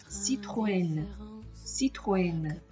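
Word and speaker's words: Citroën: 可是当你的 a 上有两个点的时候，就不可以再和 a n 进行组合，那分解来读，自己读自己的音，所以它的发音就是 Citroen，Citroen。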